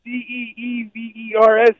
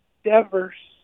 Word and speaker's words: Devers: Say Thievers. And then he tried to say, it was phonetically correct, Deevers, and you said that's Devers.